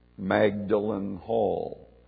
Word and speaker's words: Magdalen: Magdalen Hall, again, the English pronunciation.